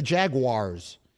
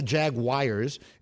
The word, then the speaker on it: Jaguars: Yeah, see, it's the Jaguars. It's not the Jaguars. It's not spelled like it was J-A-G-W-I-R-E-S. But so many people say that.